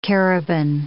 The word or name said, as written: Cariban